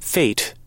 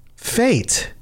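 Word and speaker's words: fête: So originally I thought it was fete. Because I thought I had never seen it. Uh-huh. In game, somewhere, I heard it pronounced feet. – Okay, so that's in Google, it says it's pronounced F E with a little the little triangle above it, whatever that symbol is called. – Yep. – Here's what it sounds like.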